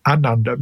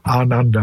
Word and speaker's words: ananda: Now, the long A, ananda, means great. So the root meaning of ananda, when you look at the words, is great contentment which, you know, could easily be translated as bliss.